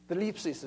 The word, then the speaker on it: thlipsis: Thlipsis is the way it's pronounced, T-H-L-I-P-S-I-S, thlipsis.